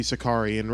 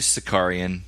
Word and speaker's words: Sakaarian: It should be Sakaarian, right? – It is Sakaarian.